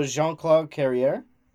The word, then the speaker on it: Jean-Claude Carrière: Oh, Jean-Claude Carrière.